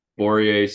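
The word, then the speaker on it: Börje: Borje Salming. – Is it Borje? – Borje Salming, yeah.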